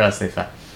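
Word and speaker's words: Asefa (אסיפה): I was reading it in English, actually, but that's the English word for Asefa. – In Hebrew, the syllable is on the last syllable, not on the first one.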